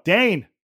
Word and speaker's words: Dane: Mark Dean, it's pronounced... No, Dane. Mark Dane, it's pronounced like Dane.